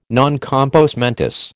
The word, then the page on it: non compos mentis: American English